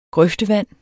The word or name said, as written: grøftevand